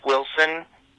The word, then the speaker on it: Wilson: It's pronounced Wilson, and I have a very big penis.